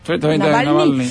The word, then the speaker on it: Navalny: que es Navalny, ¿cómo se dice? – Sí, Navalny.